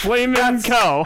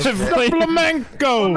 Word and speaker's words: flamenco: Wheel of Fortune contestant mispronounces, I can't even say that, Flamin' Co. – That's why I picked it. I think we should send you on Wheel of Fortune and you would do a lot of great jackass. – Flamenco.